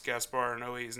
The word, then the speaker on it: Gaspar Noé: I had to go and do a deep dig to find out how to really pronounce Gaspar Noé's name. And it's Gaspar Noé. Because I had heard people say Noé. And I had heard it said no.